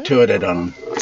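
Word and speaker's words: Tuatha Dé Danann: The Tuatha De Danann? – Tuatha De Danann.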